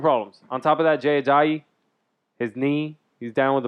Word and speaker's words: Jay Ajayi: Jay Ajayi.